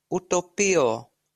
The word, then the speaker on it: utopio: U-T-O-P-I-O. And here, I'm going to play it for you. – Utopio. Utopio. – So, this is spelled U-T-O-P-I-O. But see how he's pronouncing that? See how he's pronouncing it? He's saying utopio.